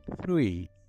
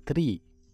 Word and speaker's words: three: Essa palavra é bem complicada porque nós não temos essa pronúncia no português. É o número 3. Three. Three. Mesmo para os estudiosos de língua inglesa, é uma palavra bem complicada porque Você pode falar tree, tree, que é árvore, tá?